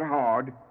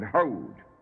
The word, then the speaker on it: Hod: Eight is Hod, not hard, but Hod, glory.